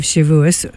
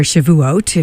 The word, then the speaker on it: Shavuot: And that begins the two-day holiday of Shavuos, or Shavuot, if you're Sephardic pronunciation.